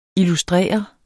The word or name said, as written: illustrere